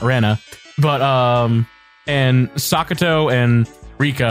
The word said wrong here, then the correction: Satoko: I think you said Sakuto, I think. You were close. It's Satoko.